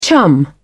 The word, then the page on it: chum: British English